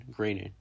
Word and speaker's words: Groening: I said I wasn't going to say Matt Gronin, but then I did instead of his actual pronunciation, Groening. Uh, personal story.